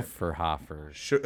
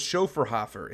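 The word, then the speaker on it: Schöfferhofer: Had some, what is it, Schnaufenhofer? I can't think of how to say it. – Schoferhofer. – Oh, it's Schoferhofer. It's you didn't even tell me we were drinking in Ireland.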